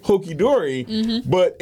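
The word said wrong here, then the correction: hunky-dory: in your, did I say that right? – You said hunky dory. – Yeah, hunky dory. – Hokey pokey, hunky dory, not hokey dunky.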